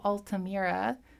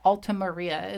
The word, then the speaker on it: Altamira: So this is The Caves of Altamira. That's a really weird word for me to pronounce. I keep like trying, it's like Altamaria is what I keep trying to pronounce it as.